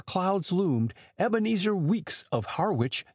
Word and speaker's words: Harwich: Harwich is the way it is mouthed in the seaport town of Essex County, England. The Harwich of Cape Cod is pronounced Harwich.